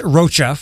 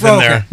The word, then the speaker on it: Rocha: Did I get your name right? – You did. Rocha? Yeah. – Okay. Rocha? – Yes. – Lourdes Rocha. We got it.